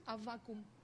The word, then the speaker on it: Аввакум: How do you say it? – Avakum. – Habakkuk what? – Avakum. – Avakum. – Avakum. – Avakabu? – Just like a vacuum.